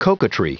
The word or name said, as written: coquetry